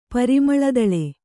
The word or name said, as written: ಪರಿಮಳದಳೆ